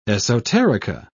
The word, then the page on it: esoterica: British English